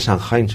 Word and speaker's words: Samhain: Alguno por ahí es Ángel, que dice, esto se pronuncia Samhain, seguro. – Bueno, eso suena muy germánico, pero no, era... Es Samhain.